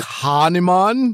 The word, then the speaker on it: Kahneman: But Kahneman? – It's K-A-H-N-E-M-A-N. You pronounce it like he's from the south of India.